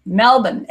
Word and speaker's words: Melbourne: Melbourne. And you know, I've learned that you, you have to pronounce it that way. It's Melbourne, not Melbourne.